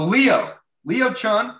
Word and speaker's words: Leo Chun: So Leo Chun, did I say it right? – Yes, you did.